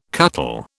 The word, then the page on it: cuttle: British English